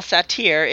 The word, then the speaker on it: Sartre: Jean-Paul Sartre is sitting in a French cafe, revisiting his draft of being in nothingness. – He says to the waitress, "You'll find that is pronounced Sartre."